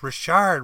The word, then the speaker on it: Richard: And midway through the game, this guy's parents are blowing me up saying, no, it's Richard, Richard, like Maurice Richard. You're butchering his name.